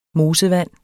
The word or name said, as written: mosevand